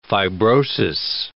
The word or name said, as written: fibrosis